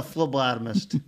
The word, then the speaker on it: phlebotomist: No, phlebotomist.